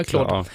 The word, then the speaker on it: Claude: Och Anthropic då är en av de största AI-företagen och de har en chatbot som heter Claude, tror jag den uttalar, eller det är Cloud? Ja, Claude. Och